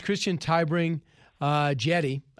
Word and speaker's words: Christian Tybring-Gjedde: So listen to, his name is Christian Tybring-Gjedde. I think that's how you say his name. Let's listen. – That is correct.